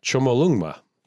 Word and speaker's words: Chomolungma: In Tibetan, it's Chomolungma.